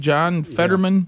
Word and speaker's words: John Fetterman: John Fetterman? – Yeah.